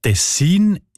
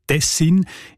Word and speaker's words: Tessin: Die Variante mit der Betonung auf den ersten Silben und mit kurzem I, also Tessin, ist seltener.